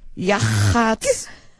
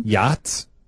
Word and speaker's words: Yachats: Y-A-C-H-A-T-S. How would you say that? Yachats. – Yachats? – Y-A-C-H-A-T-S. Yachats. Yachats, Oregon? – Yachts? – No. – No? – There's an A. – No. There's an A in Yachts. – It's Y-A-C-H-A-T-S.